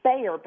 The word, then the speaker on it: Baer: I don't know if you have ever read Jay Bayer, but it's J-A-Y, and then his last name is Bayer, but it's spelled B-A-E-R, like Bayer.